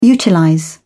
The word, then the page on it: utilize: British English